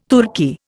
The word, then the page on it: Turki: Indian